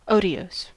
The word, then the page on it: otiose: American English